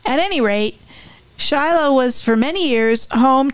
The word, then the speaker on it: Chilo: When you travel the beautiful Ohio River Scenic Byway, you'll pass through a little town called Shiloh, spelled C-H-I-L-O. We don't know why the name's pronounced this way, but we do know another town in Ohio near Coshocton that's spelled C-H-I-L-I and pronounced Chili.